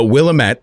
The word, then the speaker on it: Willamette: But Willamette is pronounced Will Amet. Amet. Emphasis on the second syllable.